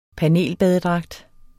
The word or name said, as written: panelbadedragt